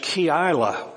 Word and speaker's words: Keilah: We come to verse 1 and we read, when David was told, he has his intelligence apparatus, look, the Philistines are fighting against Keilah, that's the way it's pronounced.